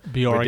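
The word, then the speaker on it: Björk: How do you say the name again? – You don't say the J. – Bjork. – Bjork, yeah.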